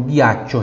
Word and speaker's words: ghiaccio: Então, unghia, unghia, U-N-G-H-I-A. Outros exemplos. Ghiaccio. Ghiaccio. Tá vendo? Eu tenho G-H-I, fica Gui. Ghiaccio, né? E o I tem som de Ti. Então, ghiaccio.